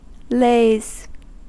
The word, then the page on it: lays: American English